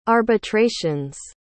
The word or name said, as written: arbitrations